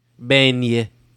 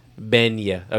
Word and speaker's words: beignet: Beignon. – Here we go. Beignet. – That's hard to say. – Yeah. And it's spelled B-A-I-G-N-E-N-T. Mm. Hold on. Beignet.